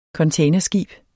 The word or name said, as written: containerskib